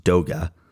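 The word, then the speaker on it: Doege: His name is pronounced Doga, like toga.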